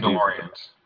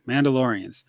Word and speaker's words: Mandalorians: Mandalorians. – Thank you. Mandalorians.